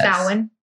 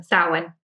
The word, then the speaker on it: Samhain: Like recently, can someone pronounce what Halloween is called with this? – Samhain. – Samhain? – Samhain. – Thank you. There you go.